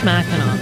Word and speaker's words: Mackinac: All pronounced Mackinac. – There we go. – Apparently, it's just a difference in the spelling, not the pronunciation.